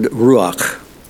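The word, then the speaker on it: ruach (רוח): Spirit, of course, is from the Hebrew word ruach. Wow, I did it right this time. The C-H at the end of ruach is kind of hard to pronounce in Hebrew. It's a guttural letter, and I either overdo it or underdo it trying to pronounce it, and managed to come off okay this time.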